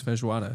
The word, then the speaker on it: feijoada: So my other thing is feijoada. It's Brazilian comfort food. I really like that you're pronouncing it right.